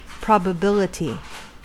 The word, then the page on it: probability: American English